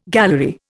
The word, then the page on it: gallery: Indian